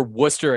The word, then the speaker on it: Worcester: Well, he plays for Worcester Academy, which if you're not from here or you don't live here, is spelled W-O-R-C-E-S-T-E-R. That's Worcester. – Worcester. – Spelled nothing like it sounds.